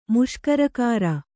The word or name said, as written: ಮುಷ್ಕರಕಾರ